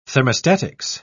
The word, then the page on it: thermostatics: British English